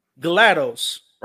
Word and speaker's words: GLaDOS: So Clay, the Brains, GLaDOS, right? I want to make sure. – Yep. – Okay, I just want to make sure I said it right.